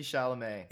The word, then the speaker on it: Chalamet: Timothy Chalamet? How do you pronounce his last name? – Yeah, Timothy Chalamet.